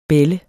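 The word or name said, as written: BÆLLEDE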